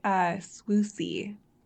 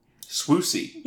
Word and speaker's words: Swoosie: Swoozie, fantastic name. That rules. I know this. I imagine that's a... Oh, okay. – Yeah, it's pronounced Swoosie. – Swoosie. – Yeah, like rhymes with Lucy.